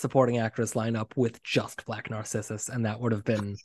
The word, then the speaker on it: Black Narcissus: Just because I'm seeing it right now, I mean, the color win for cinematography is Black Narcissus.